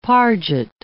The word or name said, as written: parget